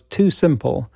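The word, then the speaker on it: TuSimple: One of the companies that Missy mentioned was Too Simple, which is a company developing autonomous trucks.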